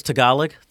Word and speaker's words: Tagalog: The first one is Tagalog.